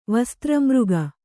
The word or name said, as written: ವಸ್ತ್ರಮೃಗ